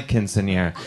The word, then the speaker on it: quinceañera: Quinceañera. Yeah, yeah. We had it. I was having my quinceañera. – Oh, yeah. It was your quinceañera. – It was my quinceañera.